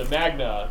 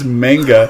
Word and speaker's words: manga: My point is, the word is manga.